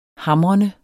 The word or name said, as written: hamrende